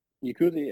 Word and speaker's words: Yakuza: Yakuza, yeah.